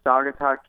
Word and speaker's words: Saugatuck: Well, so tell us a little bit about, first of all, am I saying Saugatuck Cures? Is that how I pronounce it? – Yeah, that's correct. Saugatuck Cures.